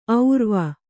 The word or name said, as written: ಔರ್ವ